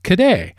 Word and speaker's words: cadet: In French, the word cadet is pronounced cadet, and the Scots changed it to caddy.